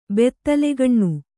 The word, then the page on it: ಬತ್ತಲೆಗಣ್ಣು: battalegaṇṇu